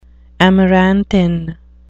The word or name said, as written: amaranthine